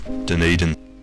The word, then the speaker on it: Dunedin: The proper pronunciation of the city's name is Dunedin.